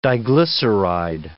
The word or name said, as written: diglyceride